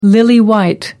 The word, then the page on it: lily white: American English